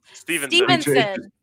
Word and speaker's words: Stephenson: For Robert Stephenson. Did I say that right? – No. – Damn. Stevenson. My brain.